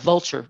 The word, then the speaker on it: Vulture: Vulture, it looks like vulture when you write it in English, but it's pronounced Vulture.